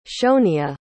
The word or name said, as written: shoneah